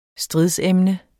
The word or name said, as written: STRIDSEMNET